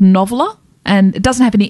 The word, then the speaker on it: NOVLR: Noveler.